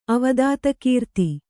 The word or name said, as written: ಅವದಾತಕೀರ್ತಿ